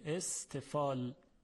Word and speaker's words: استفال: عرض کردیم که صفت متضادی که در مقابل استعلاء قرار داره صفت دیگری است به نام استفال، با سین و ته دو نقطه نوشته میشه، استفال.